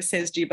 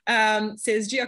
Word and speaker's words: Sezgi: It's not actually Sezgi, but I'm pronouncing it in American. Sezgi Akar on Instagram, and the same name on YouTube. You can find me on YouTube as Sezgi Akar as well.